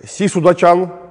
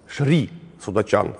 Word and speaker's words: Шри Судачан: И вот когда король Чайрадча умер после своего 13-летнего правления в 1546 году, началась чехарда, потому что у него была супруга Си Судачан, ну, конечно, правильно говорить, Шри Судачан, потому что Си – это тайское произношение индийского слова Шри, то есть уважаемая, почтенная, уважаемый, почтенный, благословляемый Шри.